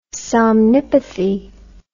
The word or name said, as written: somnipathy